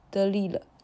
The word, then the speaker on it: Delisle: Delisly 是吧？ – 大家好，这边是不录的章鱼。之前马奇问我这个作者的法语读音啊，其实应该是 Guy de Lille，就是他这个姓中的 s 实际上是不发音的，因为它是加拿大魁北克区的法语，所以有些地方和法国的读音是存在相当差异的。